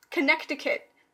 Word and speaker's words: Connecticut: Like C-O-N-N-E-C-T? – Connecticut? I-C-U-T? – Connecticut. That's how it's spelled, but you don't pronounce the C.